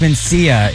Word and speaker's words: Mencia: Is it Carlos Menija? – my ninja. – Name? – Carlos Mencia is trending.